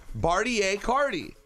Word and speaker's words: Bartier Cardi: Bartier Cardi. – I know, I just don't understand it. It's like a Neiman Marcus theme song. – It's Cartier, but with a B.